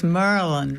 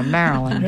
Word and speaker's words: Maryland: And that was Merlin, right? – Yes. Silver Spring, Merlin. – Thank you. – Didn't I say it right? – she did it like a northerner, Maryland.